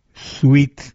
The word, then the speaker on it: Swete: Según un comentarista sobre el Evangelio de Mateo, un escritor de las palabras de Jesús, llamado Sweet, según H.B. Sweet, se escribe S-W-E-T-E.